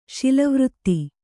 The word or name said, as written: ಶೀಲವ್ರತ